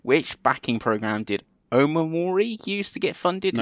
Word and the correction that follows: Omori: No, I don't know where you're getting that extra syllable from. – Okay, what? How do you say it then, Andy? – Omori. – Okay. Omori.